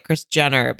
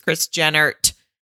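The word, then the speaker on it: Jehnert: And he even said, when you say his name, it comes out like Kris Jenner, but it's Kris Jennert with the T, and it's J-E-H-N-E-R-T.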